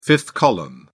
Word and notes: fifth column: British English

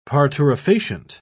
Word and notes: parturifacient: British English

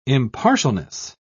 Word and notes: impartialness: British English

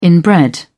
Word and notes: inbred: British English

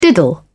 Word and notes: diddle: British English

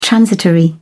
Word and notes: transitory: British English

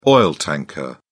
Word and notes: oil tanker: British English

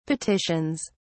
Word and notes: petitions: British English

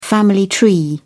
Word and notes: family tree: British English

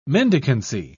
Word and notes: mendicancy: British English

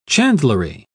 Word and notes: chandlery: British English

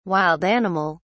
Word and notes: wild animal: British English

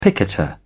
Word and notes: picketer: British English

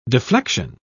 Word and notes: deflexion: British English

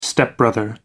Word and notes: stepbrother: American English

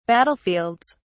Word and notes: battlefields: British English

